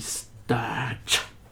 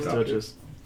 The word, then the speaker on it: stirge: Und die Sturge. – Die wer? – Die Sturge. Wie schreibt man das? S-T-U-R-T? – Korrekt. S-T-I-R-G-E.